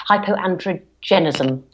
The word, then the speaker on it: hyperandrogenism: hyperandrogenism.